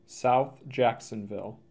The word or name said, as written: South Jacksonville